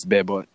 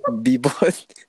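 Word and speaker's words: Bebot: Oh, you butchered the fuck. It's Be Bought. – Oh, my bad. – Be Bought.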